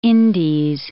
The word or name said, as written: Indies